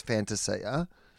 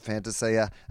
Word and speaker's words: Fantasia: And Dyson Heppel's gone, well, technically it's Fantasia. So then they say, well, would you like us to pronounce it Fantasia? And Dyson Heppel, the captain of Essendon, has gone, yeah, it probably should be Fantasia.